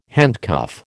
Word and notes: handcuff: American English

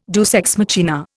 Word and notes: deus ex machina: Indian

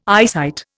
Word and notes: eyesight: Indian

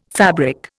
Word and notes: fabric: Indian

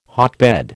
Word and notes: hotbed: American English

